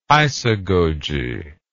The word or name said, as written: isagoge